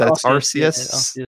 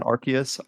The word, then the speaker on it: Arceus: It's Arceus. Soft C, not Arceus. Arceus.